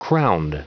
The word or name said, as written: crowned